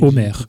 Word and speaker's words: Omer: why not make it simple and call it Omer, O-M-E-R? Because in French you don't pronounce the H anyway.